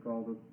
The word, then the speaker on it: Qal: A given verb can occur in what they call the call form. It's spelled Q-A-L, which means the simple form.